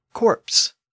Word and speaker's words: corpse: And over time, people started to pronounce the P in this version of the word, even though it had been silent up to this point. The result was the word corpse, C-O-R-P-S-E, meaning a dead body.